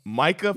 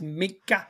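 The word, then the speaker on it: Minkah: Micah Fitzpatrick? – I believe it's pronounced Micah.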